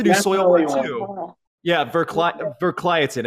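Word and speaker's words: Verkligheten: I had to do the album review on it, so I had to keep looking it up on how to pronounce this. I'm pretty sure, if I'm remembering correctly, it's Verkliatin, but yeah.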